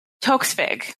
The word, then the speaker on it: Toksvig: And then they brought in two new hosts, Noel Fielding and Sandy Tovig, I believe. Toksvig. – Yeah, so I was trying to figure out how to say her name earlier, and I was like, Toksvig. Yeah, you've got it.